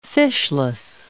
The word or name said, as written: fishless